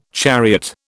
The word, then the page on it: chariot: British English